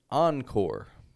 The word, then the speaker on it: encore: It is a mix of an American and French pronunciation. I'll show you an example. The word encore.